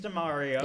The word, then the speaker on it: Damore: Well, James Damore later, or Damore, however you pronounce it, later on said something like Mario. It's pronounced Mario. – Oh, James DeMario. – Yeah, I wouldn't trust you anyway.